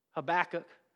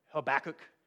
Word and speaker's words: Habakkuk: Habakkuk. I'm saying that to correct any wrong pronunciation. Habakkuk. I just say it's like tobacco with an H. Habakkuk.